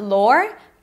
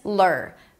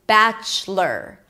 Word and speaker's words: Bachelor: And then the most important thing here is that you don't pronounce the O. It's not lore, but it's lur. Bachelor.